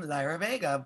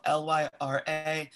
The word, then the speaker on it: Lyra Vega: All of my handles are the same for every platform, and my handle is It's ITS, and then my name, Lyra Vega, which is spelled LYRAVEGA.